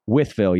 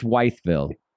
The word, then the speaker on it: Wytheville: Withville, yes, Withville. – Okay, Withville. – Yes, okay. – not Wytheville.